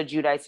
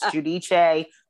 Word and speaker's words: Giudice: So not like a Teresa Giudice situation where we may or may not know how to pronounce it. Some days it's Giudice.